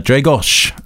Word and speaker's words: Dragos: Dragos. – Dragos. Exactly. – Yes. – So with the final S pronounced like the shy, Dragos.